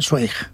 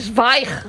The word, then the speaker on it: Zweig: Tú sabes que yo toda mi vida he estado diciendo que yo leía Stephen Zweig. – Ah, claro. Es no. Zweig.